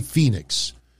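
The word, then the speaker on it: Fénix: Maybe Ray Fenix, we say Phoenix, but Excalibur is, you know, like, you know, Konosuke Takeshita.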